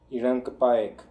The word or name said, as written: Irankipaekh